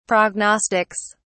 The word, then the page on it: prognostics: British English